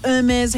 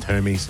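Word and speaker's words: Hermès: And then people who buy these beautiful Hermes handbags. – It's pronounced Hermes.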